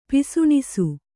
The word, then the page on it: ಪಿಸುಣಿಸು: kannada